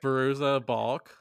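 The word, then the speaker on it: Fairuza Balk: And it starred, say her name again, because I don't want to butcher it. – Ferruja Balk. – Yes.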